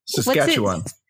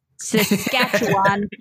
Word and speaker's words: Saskatchewan: And so they shot it up in, I'm going to butcher this, Saskatchewan? – Saskatchewan. – Yes. Saskatchewan. wow. Tongue twister. Or maybe I just don't speak Canadian. – I think that's right.